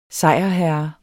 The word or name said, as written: SEJRHERRENS